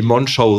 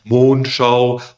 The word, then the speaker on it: Monschau: Einer der wenigen, die Monschau richtig sagen und nicht Mondschau oder Mondschau, einfach Monschau.